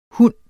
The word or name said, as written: hund